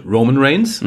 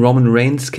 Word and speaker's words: Roman Reigns: Komisch betont, Roman Reigns. Mhm. So betont man den ja gar nicht. Roman Reigns heißt das.